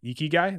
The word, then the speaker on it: ikigai: the Japanese concept of ikigai.